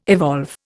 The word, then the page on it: evolve: Indian